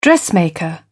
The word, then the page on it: dressmaker: British English